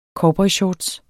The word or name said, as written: cowboyshorts